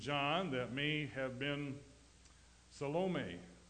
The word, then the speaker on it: Salome: You know, the E is pronounced, it is long. Fortunately, the O in her name is long also, or her name would be Salami, which would be a terrible thing, eh? But it's not. Her name is Salome, Joanna, the wife of Herod Steward Cusa.